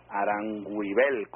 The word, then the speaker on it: Aranguibel: Y les recordamos nuestro contacto a través de nuestra cuenta de Twitter, @soyaranguibel, que se escribe, ustedes saben, Aranguibel, con B alta. Te lo digo porque muchas veces la gente nos pregunta, ¿cómo es que es el apellido? ¿Cómo es que se pronuncia?